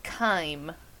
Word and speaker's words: chyme: how do you actually pronounce this? Because I feel like I'm thinking of it wrong. – Yes, it is called chyme.